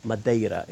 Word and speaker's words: Madeira: Madeira is a Portuguese pronunciation meaning wood.